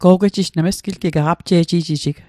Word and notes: go'gwejij: Mi'gmaq

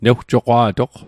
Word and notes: newjoqwa'toq: Mi'gmaq

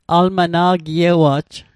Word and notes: almana'giewa'j: Mi'gmaq

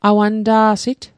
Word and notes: awanta'sit: Mi'gmaq

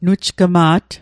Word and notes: nujgma't: Mi'gmaq

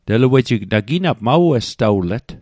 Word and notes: westawlet: Mi'gmaq

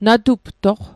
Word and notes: natuptoq: Mi'gmaq

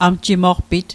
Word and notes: amjimoqpit: Mi'gmaq

